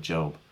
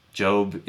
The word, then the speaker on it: Job: And I'm not sure that my comparison here is totally accurate, but this really started making me think of the book of Job, where Job is the... – It's pronounced Job. I'm sorry.